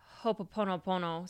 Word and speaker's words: Ho'oponopono: Yeah, and the word Ho'oponopono, so I was pronouncing it correctly, roughly translates to cause things to move back into balance or to make things right.